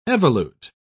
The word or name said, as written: evolute